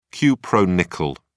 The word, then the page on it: cupronickel: British English